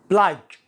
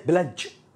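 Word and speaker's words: pledge: بلاج بلاج وليست بلج بلج بلاج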